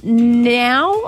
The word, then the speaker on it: Nau: We are looking for Tara Now, I want to pronounce it, N-A-U, Tara Now from Dapto.